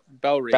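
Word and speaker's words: Bellerive: Belle Reve, I believe is correct.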